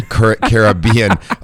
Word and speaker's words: Caribbean: So is it the Caribbean or Caribbean? – Caribbean. – That's really true too, as well. – I had a newscaster say the other day, the Caribbean, and I thought, I don't think that's the way it's supposed to be.